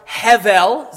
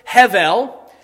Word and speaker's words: Hevel: She gave birth to his brother Abel, or Hevel, is actually how it's pronounced in the Hebrew, Hevel.